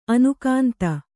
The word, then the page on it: ಅನುಕಾಂತ: Kannada